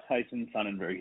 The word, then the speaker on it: Tyson Sonnenburg: Yep, uh, Tyson Sonnenburg.